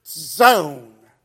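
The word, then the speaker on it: zone (צאן): Hebrew noun there is zone. Sort of T-Z-S-O-N-E. They don't spell it that way in Hebrew, but for pronunciation, zone.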